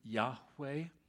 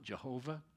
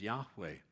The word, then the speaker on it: Yahweh: It translates the Hebrew Yahweh, Y-H-W-H. God's name is not Jehovah. They don't have a J or a V in their alphabet. It'd be very difficult to pronounce that for a Hebrew.